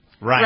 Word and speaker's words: Reince: What's the how you pronounce it? – Reince. – Reince. Mm-hmm. Yeah, okay.